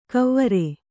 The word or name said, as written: ಕವ್ವರೆ